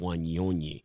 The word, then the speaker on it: Wanyonyi: And the way the 18-year-old did it, I threw in an extra syllable there, if you will. It's right. Wanyonyi.